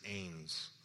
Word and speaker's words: Anes: My last name is Ains. It's spelled A-N-E-S, okay?